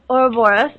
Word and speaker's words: Ouroboros: It's the serpent to bear, and it's on the Ouroboros.